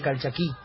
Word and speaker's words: Calchaquí: Calchaquí. – No se dice calchaquí, se dice calchaquí.